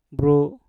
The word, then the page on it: bru: Mpur